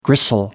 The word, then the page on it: gristle: American English